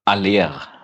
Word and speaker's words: Haller: wie wird er ausgesprochen, Haller, Halle, Franzose, glaube ich, ne? Aller. Aller.